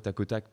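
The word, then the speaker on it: Takh: Donc, tac attention, euh, petit, petit piège, ça s'écrit T-A-K-H-O-T-A-K-H. Alors pourquoi tac Parce que bah, l'expression, c'est rapide, c'est simple.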